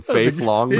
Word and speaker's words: Fei Long: I think you added an L to his middle name, Fei Long.